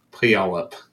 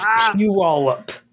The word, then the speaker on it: Puyallup: Puyallup. – It's Puyallup.